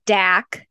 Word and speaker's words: Dak: His name was Dak, rhymes with Zach.